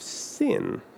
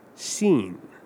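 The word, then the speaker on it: Zin: Now, some of you may have accidentally referred to this or you may have noticed as I read it, you, that this is not the desert of sin, it's the desert of seen. Now, that pronunciation makes a huge difference because in Hebrew, there is no similarity between sin and seen.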